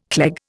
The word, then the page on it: cleg: Indian